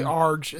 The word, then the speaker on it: Arj: Well, it's actually Arj is how it's pronounced. – Yeah, it's Arj. – But everyone calls him RJ.